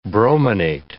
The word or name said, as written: brominate